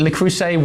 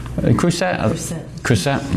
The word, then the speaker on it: Le Creuset: The Crusade, how do you say that? Le Creuset wok, and crucially with a lid. Creuset.